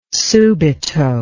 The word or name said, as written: subito